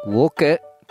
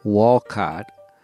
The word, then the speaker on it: Wolcott: Wolcott, not never, Walcott. Wolcott is on Route 15. It's in Lamoille County, I believe. You can say Wolcott, but if you really want to sound local, it's Wolcott.